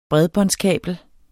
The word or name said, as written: bredbåndskabel